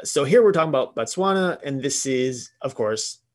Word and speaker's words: Botswana: The real, we've all heard the term, Garden of Eden, has been traced to the African nation of Botswana. I think I'm pronouncing that right. I hope. – Yep, you got it.